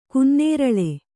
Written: ಗನ್ನೇರಳೆ

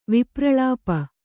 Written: ವಿಪ್ರಳಾಪ

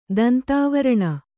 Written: ದಂತಾವರಣ

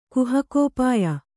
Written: ಕುಹಕೋಪಾಯ

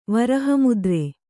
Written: ವರಹಮುದ್ರೆ

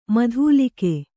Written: ಮೂದಲಿಕೆ

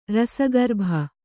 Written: ರಸಗರ್ಭ

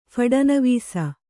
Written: ಫಡನವೀಸ

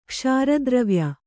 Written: ಕ್ಷಾರದ್ರವ್ಯ

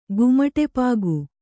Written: ಗುಮಟೆಪಾಗು

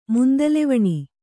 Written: ಮುಂದಲೆವಣಿ

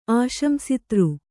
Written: ಆಶಂಸಿತೃ

